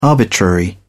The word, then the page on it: arbitrary: British English